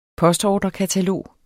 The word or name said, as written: postordrekatalog